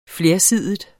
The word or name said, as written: flersidig